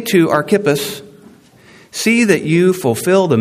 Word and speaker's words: Archippus: I wanted to say Archippus, but according to the app, it's Archippus. I'll go with that.